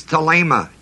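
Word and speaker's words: thelema (θέλημα): The word will is thelema, T-H-E-L-E-M-A, long E, second E.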